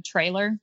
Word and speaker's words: Traylor: My email is simply my first initial R, my last name Traylor, T-R-A-Y-L-O-R. It's, you know, Taylor with an R, and also not trailer like tractor trailer.